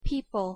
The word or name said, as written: people